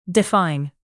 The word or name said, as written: define